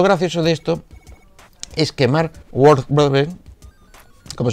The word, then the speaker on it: Mark Wahlberg: está casi confirmado que Mark Wahlberg, ¿lo he dicho bien? Mark Wahlberg. Eso.